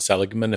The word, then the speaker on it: Seligman: And then I believe it's Martin Seligman, if I'm saying his name right.